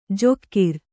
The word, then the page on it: ಜೊಕ್ಕಿರ್: jokkir